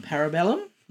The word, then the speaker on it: Parabellum: Parabellum, rather. – Yeah. – Parabellum. There's no A at the front. You were saying a parabellum. – I didn't say that. I said parabellum.